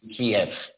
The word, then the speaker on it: Kyiv: When I visited Kyiv, it was Kyiv. And when I talked with some of my contacts there and specifically asked them, how do you pronounce this city? It is most definitely Kyiv.